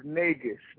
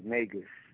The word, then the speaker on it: Negus: The pronunciation is Negus, like Vegas. – Negus. – Yeah, Negus.